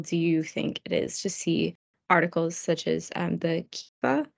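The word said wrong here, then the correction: keffiyeh: I'm probably mispronouncing that. Sorry. The Kifa, the Palestinian scarf. Kafia. – The Kafia.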